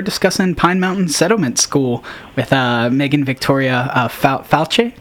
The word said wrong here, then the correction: Falce: It's false. – Sorry. – Like true or false.